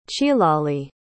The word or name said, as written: chilali